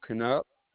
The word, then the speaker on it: Knupp: My name was William Knupp.